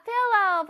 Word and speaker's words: pillow: 자, pillow 발음 다시 해볼게요. L이 두 개야. L 두 개니까는 혓바닥이 앞으로 쫙 나와야 돼.